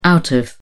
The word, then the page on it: out of: British English